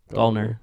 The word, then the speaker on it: Gallner: And then Kyle Gallner? – Let me see. – I forgot. I'm saying his name wrong. He was on Smile 1. – Gallner, yeah.